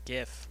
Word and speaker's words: GIF: Do you pronounce it GIF or JIF? – GIF. – GIF.